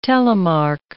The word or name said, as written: telemark